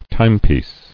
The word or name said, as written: Timepiece